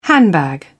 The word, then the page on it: handbag: British English